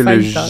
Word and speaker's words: fajitas: Oui, il faut savoir que fajitas. Le J en espagnol, souvent, se prononce pas ou en R.